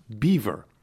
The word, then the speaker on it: Belvoir: First off is Belvoir, a famous farm and old estate, and it is pronounced Beaver.